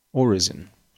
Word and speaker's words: orison: O-R-I-S-O-N. Why is everybody in this house awake at 2 o'clock in the morning? Orison. Definition. Research machine is telling me they can get it cranked up. Oh, prayer. Just a prayer. Pronounced orison. Orison. Like Roy Orbison, but just an orison.